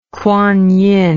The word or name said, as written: Kwan Yin